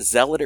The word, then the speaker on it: zealot: Is it zealot or zealot? – Zealot. – I can't. Zealot. – Zealot, yeah.